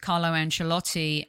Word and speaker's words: Carlo Ancelotti: And let me just tell you that when I was on the conference with Carlo Ancelotti and Thibaut Courtois, how'd I do with my pronunciation there, Tom? – That was very good.